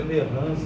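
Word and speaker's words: Lérins: Temos o monarquismo de Lerrance, na, no sul da, da França, né? que a pronúncia é Verrans mesmo, tá? com o S.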